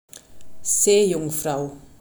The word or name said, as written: seejungfrau